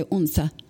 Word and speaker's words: Odense: And then in September we went out, and in Odense is the way it's spelled. They say Unsa.